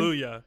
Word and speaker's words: Kaluuya: How do you say his Kaluuya. – Name? – Kaluuya. Yep.